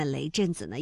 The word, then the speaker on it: 雷震子: 像雷震子的子啊，它是古代对于男性的常见后缀，作用是构成完整的人名。那之前呢，我们也在节目当中梳理过啊，类似于像孔子、孟子，这个子呢，虽然没有实意，但是要保持声调来凸显人名的完整性，那雷震子呢也是如此哈。